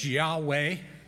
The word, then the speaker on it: Yahweh: And the Father image is YHWH, Yahweh, is how we pronounce that.